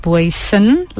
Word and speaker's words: Boysen: Let me first say my surname is actually pronounced Boysen, like Boysen. With an N. Yes, mm-hmm. With Boysen. An N on the end.